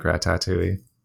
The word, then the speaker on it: Ratatouille: I really like Ratatouille. – I like how you pronounce it. – Ratatouille. You Ratatouille. Have to say Ratatouille every syllable.